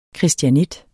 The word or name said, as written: christianit